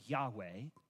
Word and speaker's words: Yahweh: We don't actually know how to pronounce it, but kind of the prevailing view today is that it's pronounced Yahweh.